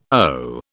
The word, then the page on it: owe: British English